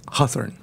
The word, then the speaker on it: Hawthorne: Hawthorne. Fíjate que es como, como de una sílaba. Es como de una sílaba como lo pronuncia.